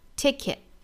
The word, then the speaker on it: ticket: 一般来说，在美语里面啊，单词的词尾或者一句话的句尾的这个 t 呢，一般是不发音的。比如说这个单词就直接发成 ticket, 而不是发成 ticket。